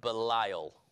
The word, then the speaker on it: Belial: In Hebrew, it's pronounced Belial. Sound familiar? And so, Belial, now technically it's about Belial, but it's Belial.